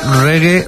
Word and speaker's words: reggae: que nosotros la escribimos R-E-G-G-A-E, pero en realidad ellos, la primera vez que lo escribieron, era un poco más como se pronuncia, que es reggae, reggae, o sea, R-E-G-G-A-Y.